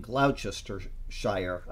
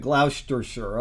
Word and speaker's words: Gloucestershire: After Joseph's first wife died childless, he married Freelove Collett, a member of a Baptist church in Gloucestershire. I know that's not the way you pronounce it. – Gloucestershire.